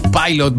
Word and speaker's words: Pilot: Eso, cuídate mucho y porfa, si me regalas el tema Magic de Pilot o Pilot, ahí tú me corriges cómo se pronuncia. Eso, besitos, cuídate. – Está bien pronunciado, pa, amiga mía, si usted se maneja con el Pit in English. Pilot Magic, vale, gracias por el reporte del tiempo.